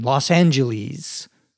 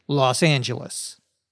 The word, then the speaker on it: Los Angeles: That's how they say it in Great Britain, Los Angeles, with the last syllable being like the word please. But here in LA, we say Los Angeles. So get it right, Brits.